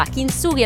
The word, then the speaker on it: Kintsugi: Mentre, che differenza c'è tra Kintsugi e Kintsugi? Che una è sbagliata e una è giusta. Kintsugi è la pronuncia giusta del nuovo album di